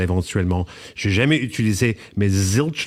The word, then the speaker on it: Zilch: Zilch.